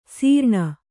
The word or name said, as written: ಸೀರ್ಣ